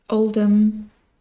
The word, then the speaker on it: Oldham: Oldham, the H just isn't pronounced really,